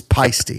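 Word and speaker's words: Paiste: We've been corrected by a listener on our pronunciation of it's Pasty. – Is it Pasty? – Pasty. – Okay. – So they said, make sure next time this comes up, it's Pasty cymbals.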